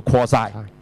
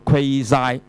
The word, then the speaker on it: quasi: 嚇、啊！咁、呢個準咧，我特登頭先又上個網聽嗰個英文啦，發音啦嚇，佢、係讀叫做 quasi 嘅 quasi，咁啊美國音咧就叫做 quasi，係英國音啊 quasi，quasi，佢叫做 quasi periodic，即係話佢係周期性，但係個周期性得嚟咧，佢又不規則嘅喎。咁、原來呢、這個。